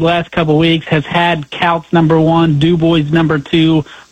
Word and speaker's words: Kouts: Is it Coates or Coots? How do you say that? K-O-U-T-S. – It's Couts.